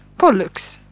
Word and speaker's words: Pollux: Jag påstår att de båda stjärnorna i tvillingarnas stjärnbild heter Castor och Pollux, tror jag man uttalar det. – Rätt.